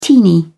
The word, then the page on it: teeny: British English